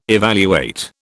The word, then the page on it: evaluate: British English